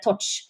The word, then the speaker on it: Txotx: Det er litt vanskelig å eh, hva skal jeg si, å uttale det, men det er Torch.